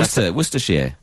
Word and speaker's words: Worcestershire: I feel like I'm watching the Food Network and one of the Americans is trying to say Worcestershire. – I've never been Worcestershire. Worcestershire. – Worcestershire.